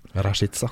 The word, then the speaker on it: Rashica: Rashica, som jeg lærte uttalen av det. – Ja takk, og jeg lærte det av min kosov-albanske kollega.